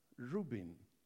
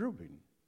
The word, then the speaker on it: Reuben: He said, let Reuben, am I saying it right? Yes. Reuben, live.